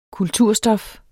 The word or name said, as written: kulturstof